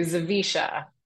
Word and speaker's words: Zawisza: So I went from Chapman to Zawisza. – Zawisza. – So that is how it's said.